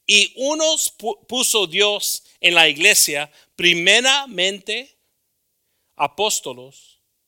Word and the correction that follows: apóstoles: do we say it right? Apóstoles? Apóstoles.